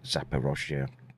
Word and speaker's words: Zaporizhzhia: Now, of course, I have to speak about Ukraine because I want to talk about the Zaporizhzhia.